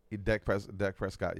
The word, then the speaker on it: Dak Prescott: Dak Prescott, yes, sir.